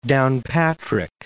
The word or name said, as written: Downpatrick